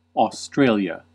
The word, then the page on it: Australien: /aʊsˈtʁaːli̯ən/